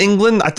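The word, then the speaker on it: Englund: Robert Eglund. – Did I pronounce... – Is it England? Okay. Yeah.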